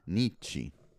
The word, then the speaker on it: Nietzsche: Rousseau, Marx, and how do you say that? Nietzsche? – Nietzsche. He's German.